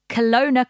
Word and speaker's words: Kelowna: And Andy, you did pronounce Kelowna correctly last yes.